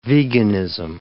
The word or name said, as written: veganism